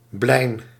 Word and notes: ampoule: /ɑ̃.pul/

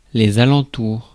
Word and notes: alentours: IPA: /a.lɑ̃.tuʁ/

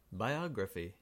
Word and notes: biography: American English; /baɪˈɑːɡɹəfi/

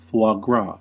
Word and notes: foie gras: [ˌfwɑː ˈɡɹɑː]; /ˌfwɑː ˈɡrɑː/

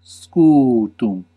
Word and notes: shield: /ʃiːld/; English

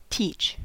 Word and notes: teach: [ˈtʰiːt͡ʃ]; /tiːtʃ/; /titʃ/

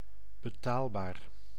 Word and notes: abordable: France; [a.bɔʁ.dabl]

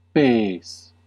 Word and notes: foot: /fʊt/

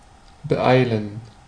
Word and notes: favoriser: France; /fa.vɔ.ʁi.ze/

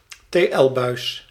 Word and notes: tl-buis: /teˈɛlbœys/

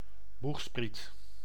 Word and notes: boegspriet: /ˈbux.sprit/

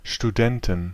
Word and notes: Studenten: /ʃtuˈdɛntən, ʃtuˈdɛntn/; /ʃtu.ˈdɛntən/

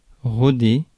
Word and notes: roder: [ʁɔ.de]; /ʁo.de/

